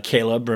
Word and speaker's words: Chleb: Caleb, remember, that's how you say his name. That's Catherine's boyfriend, or was Catherine's boyfriend. You spell it C-H-L-E-B, and I've translated that to Chubbs.